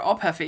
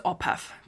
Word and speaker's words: ophef: It's spelled OPHEF. It's one word.